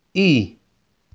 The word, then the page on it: E: Inglês